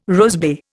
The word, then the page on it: rosebay: Indian